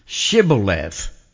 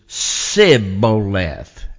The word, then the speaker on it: Shibboleth: So this word Shibboleth, they couldn't pronounce it because what they would say is Sibboleth, and they would leave that SH out of it and just go with the S.